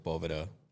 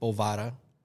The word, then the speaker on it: Boveda: It's not your bookie. It's not Bovada.